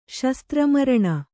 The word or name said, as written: ಶಸ್ತ್ರಮರಣ